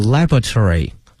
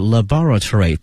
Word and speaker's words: laboratory: 这个词啊有两个发音，一个呢它是一个叫 a laboratory，还有一个呢是 laboratory，都可以哈。 laboratory 呢是美式发音， laboratory 是英式发音，大家呢习惯哪一个都可以。